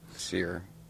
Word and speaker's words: Cyr: Sear. – Lily Saint Sear? C-Y-R?